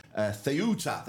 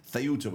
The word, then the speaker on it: Ceuta: Military personnel from Mallorca are helping with the security operation in Ceuta. That's how you pronounce it, apparently. Ceuta, which is the African enclave that is owned by Spain.